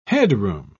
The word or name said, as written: headroom